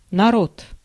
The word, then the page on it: народ: /nɐˈrot/; narod